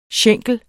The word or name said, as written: schenkel